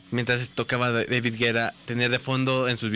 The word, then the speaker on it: Guetta: En Francia, así en su país natal, se dice David Guetta, es correcto.